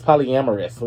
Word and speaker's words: polyamorous: So, Nick Cannon has opened up about his plumigulous. I can never pronounce this word. It's polyamorous. We're going to do polyamorous.